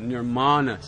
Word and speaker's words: Nirmana: That's where that's accented there because of the long syllable there. Nirmana. Kaya, see, like that. So that this is a big guide on how to pronounce Sanskrit. You always accent the long syllable if there is one.